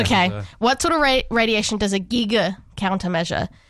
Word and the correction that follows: Geiger: Yeah, Giga, Geiger.